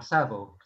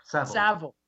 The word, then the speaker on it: Savile: This is well off topic, but while I've got you, when Seville was exposed, and am, am I am I still pronouncing that wrong? Is that you're still pronouncing Sav- it wrong? Savile. Savile.